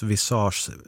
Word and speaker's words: visage: PS, visage uttalas visage, ansikte på franska.